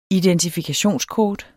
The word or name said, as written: identifikationskort